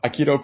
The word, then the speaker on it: Aikido: Is it pronounced Aikido? – Aikido President President of the Aikido Club at UNC Chapel Hill.